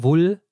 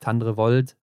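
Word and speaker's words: Tandrevold: bei den Norwegern, Norwegerinnen, Tandre Tandrevull was man so nicht kannte. Sonst mhm. hat man immer gesagt, Ingrid Landmark Tandre Volt. Also dieses D noch mit ausgesprochen. Jetzt war es so ein bisschen stumm. Und das O wird ja wohl so ein bisschen wie ein U gesprochen im Norwegischen.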